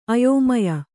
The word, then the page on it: ಅಯೋಮಯ: Kannada